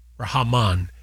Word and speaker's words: Rahman: Okay, Abdul Rahman Muhammad El Sayed. – Rahman. Yeah, there's an N on the end of it.